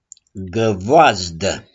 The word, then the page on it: GĂVOAZDĂ: s.f.